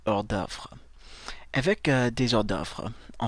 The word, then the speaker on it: hors d'œuvre: Alors, la prononciation du mot, c'est hors d'œuvre.